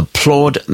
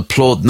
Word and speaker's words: Plaud: This is the promise of a little device called the Plaud Note Pen, and Plaud is spelled P-L-A-U-D.